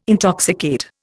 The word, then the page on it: intoxicate: Indian